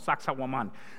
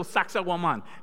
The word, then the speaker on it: Sacsayhuaman: The tour guide was trying to be funny, and he said, if you don't know how to pronounce this, you can say sexy woman. I was like, nah, dude, stop that. And so Sacsayhuaman.